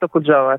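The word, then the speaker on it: Okutsava (Окуджава): Bueno, gracias a ti por estar aquí, por concedernos tu tiempo y, sobre todo por ser hoy nuestro guía hacia Okutsava, que no sé si lo pronuncio bien. – Sí, Bulat Okutsava, así es.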